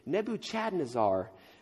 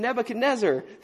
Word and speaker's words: Nebuchadnezzar: how do you say this name? Nebuchadnezzar." And I said, "Oh, you mean Nebuchadnezzar."